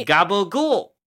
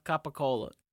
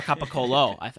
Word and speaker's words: capicola: Yeah, it's kind of my gabagool. – No, not right. a butchered pronunciation of capicola. But or capicolo, as I found out.